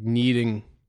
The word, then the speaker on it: kneading: Like kneading. – Yeah. – Like K. – Yeah, like knee dick. – Yeah, with the K is silent.